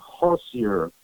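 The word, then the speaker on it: Hössjer: And I believe you pronounce his name Hossier, H-O-S-S-J-E-R, but I think they pronounce the J like an I.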